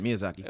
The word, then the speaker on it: Miyazaki: Yeah, Miyazaki.